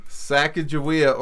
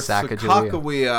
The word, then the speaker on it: Sacagawea: Sacagawea or Sacagawea, I believe, is the actual historical pronunciation.